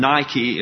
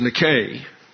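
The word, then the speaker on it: nike (νίκη): You have a noun, nike, and Nike is how we often pronounce that in English because of the tennis shoe company. But it's nike.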